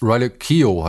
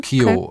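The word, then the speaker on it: Riley Keough: Riley Keo heißt, glaube ich, wird sie ausgesprochen. Q? Also ich glaube, sie wird Keo, okay. Keo ausgesprochen.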